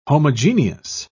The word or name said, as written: homogeneous